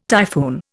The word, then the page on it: typhoon: Indian